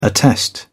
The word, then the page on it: attest: British English